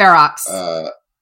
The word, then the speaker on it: Ferox: Yeah, well, I rewatched Cannibal Ferox. How do you pronounce it? – Ferox.